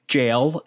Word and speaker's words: gaol: So this, I said it that way because it's spelled G-A-O-L. – Jail is? – Yes, jail. It's pronounced jail, but spelled G-A-O-L.